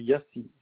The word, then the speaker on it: Yassi: Alors, le nom, c'est Yassi. Ça s'écrit YA2SI.